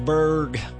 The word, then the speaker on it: Birge: We've got a lovely ride, my favorite song, George Berg. Got to say that because his name is spelled B-I-R-G-E, if you didn't know that. Luke Bryan, B-R-Y-A-N.